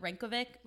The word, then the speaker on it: Reykjavik: Like, everyone was going to Reykjavik.